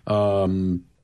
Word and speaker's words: Titanoboa: So, Karen, earlier in the show, we mentioned the exhibit at the museum, Titanoboa. Did I say that right? – Yep.